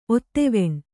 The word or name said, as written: ಒತ್ತುವಣ್ಣು